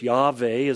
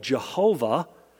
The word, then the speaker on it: Yahweh: Yahweh is the way we believe it's pronounced, as best guess. It was mispronounced for years. People called it Jehovah. And I won't go into explaining how they came to that conclusion, but we know that's not really accurate.